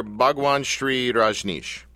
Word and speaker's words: Bhagwan Sri Rajneesh: Let me see if I get the pronunciation right here. Bhagwan Sri Rajneesh. Did I say that right? – Right.